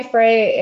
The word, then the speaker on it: Frey: Frey. It all means free meaning in German.